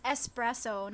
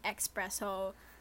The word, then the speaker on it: espresso: It's espresso, not expresso.